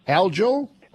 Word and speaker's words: algal: First of all, pronunciation on algal. Algal? – Algal, you got it right.